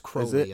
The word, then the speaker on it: Crowley: No, he's pronounced Crowley in this okay.